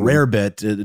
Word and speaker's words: rarebit: I mean, I know that it is sort of a corruption. Man, that sounds really intense. Of the word rabbit, uh, mm-hmm. rarebit, uh, just like a way it would have been pronounced uh, in Welsh, and so it's spelled that way, like rare bit. But it definitely is meant to be interpreted as rabbit.